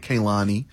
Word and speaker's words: Kehlani: They say it Kehlani. – Kehlani. I always want to say Kehlani. – Exactly. I was like, listen, if you're going to come to South Africa, your name is Kehlani.